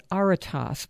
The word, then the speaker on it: aretas: This is the Greek word you found, aretas, pronounced like gravitas, A-R-E-T-A-S.